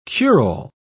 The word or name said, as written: cure-all